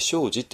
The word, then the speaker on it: 生死: これね、何回もお願いしてますけれども、まあ、一般の日本語だと生死ですね。生、生きることと死ぬことですね。生死って、生死っていう発音しますけれども、仏教では生じって読みますが、これちょっと生じっていうこと、発音を覚えちゃってください。っていうのは、ちょっと仏教的に生死について考えるときは、必ず生じって、いう発音をしますのでね。